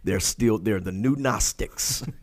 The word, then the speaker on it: Gnostics: before the Gnostics spelled with a silent G, G-N-O-S-T-I-C-S.